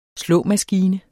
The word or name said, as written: slåmaskine